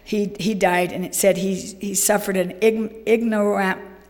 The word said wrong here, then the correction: ignominious: how do you say that? It said ignominious, which means humiliating death.